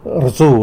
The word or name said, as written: rzu